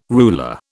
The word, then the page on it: ruler: British English